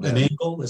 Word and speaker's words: Engl: I've been using, honestly, for recording, I've been using a modeled amp, like a virtual amp, an Engl, is that how you pronounce it?